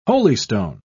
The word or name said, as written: holystone